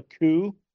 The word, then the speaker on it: coup: So it's called a coup. It's spelled COUP. It's a coup.